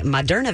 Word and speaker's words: Moderna: She sunk $1 million into the COVID-19 research that has resulted in the Moderna vaccine. Am I pronouncing that right? – Yes.